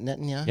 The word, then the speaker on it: Netanyahu: Netanyahu? Is it Netanyahu? – Yeah, you said it right, yeah.